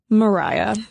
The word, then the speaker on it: Maria: Yes, it is spelled to look like Maria, M-A-R-I-A, but it is pronounced Mariah.